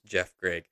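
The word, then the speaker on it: Geoff Greig: Again, his name is spelled GeoffGreig. So it's a little bit of a weird spelling there, but it's pronounced Jeff Gregg.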